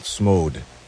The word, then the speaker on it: SMOD: That last one is from a group called SMOD. S-M-O-D. Long O.